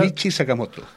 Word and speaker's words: Ryuichi Sakamoto: Ryuichi Sakamoto. No es tan difícil. Ryuichi Sakamoto. Pruébalo. Ryuichi Sakamoto. Sí. – Es como sacar la moto, ¿no? – Sí.